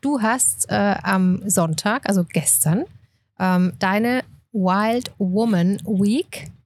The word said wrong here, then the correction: Wild Women Week: nee, Wild Women Week. – Wild Women.